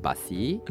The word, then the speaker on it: Busi: Some people pronounce it Busi. – No, for me, it's Busi because of the rhyme with pussy, right? – Exactly that, yes.